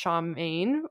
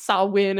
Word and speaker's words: Samhain: And so yeah, I posted this video of talking about, so it sounds like it should be pronounced Shamane, which is how I pronounce it. And this girl commented basically being like, it's Samhain.